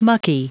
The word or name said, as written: mucky